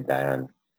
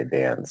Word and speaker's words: Dayan: It's, well, if you're going to be correct in terms of origin, it would be Diane from a accuracy perspective, but as you know, in our country nobody ever gets their name pronounced right, so I've grown up and had everybody say Dan, so it doesn't really matter. Answer to both.